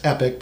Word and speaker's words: Epoch: The Epoch Times, it's pronounced Epic, but it's E-P-O-C-H, the Epoch Times,